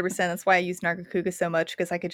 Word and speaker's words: Nargacuga: that's why I liked my Nargacuga. Narga, did I have it pronounced? Narga yeah. Kuga.